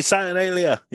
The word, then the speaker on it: Saturnalia: Saturnalia? – Hey, Saturnalia.